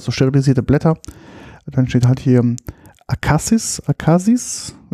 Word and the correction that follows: Akakies: würde ich sagen, wird es ausgesprochen, oder? – Mhm. – Ähm, Sparkling 2017. Ähm, genau dementsprechend. – Äh, Akakies. Ne? Akakies. Akakies. – Akakies, genau.